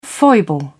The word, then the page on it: foible: British English